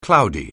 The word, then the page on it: cloudy: anglais